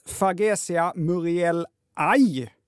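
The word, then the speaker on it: Fargesia murielae: När ett ord innehåller kombinationen A-E ska det uttalas som aj. Kan vara bra att komma ihåg när ni ska prata växter nästa gång. Hälsningar Joakim. Tack! Då ska den här sortens bambu alltså uttalas Fagesia muriellae.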